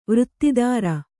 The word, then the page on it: ವೃತ್ತಿದಾರ: vřttidāra